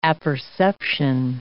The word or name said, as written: apperception